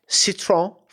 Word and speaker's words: Citroën: Citroën. It's like C-I-T-R-E-O-N, I think is how you spell it.